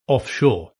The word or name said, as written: offshore